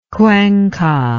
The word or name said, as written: cuenca